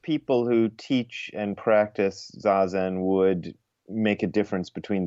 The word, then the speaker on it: Zazen: And just to get some vocab down, and tell me how to pronounce this. Zazen? – Yeah, Zazen.